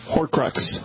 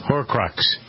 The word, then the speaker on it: Horcrux: Is that how you pronounce that from Harry Potter books? – Horcrux. – Horcrux.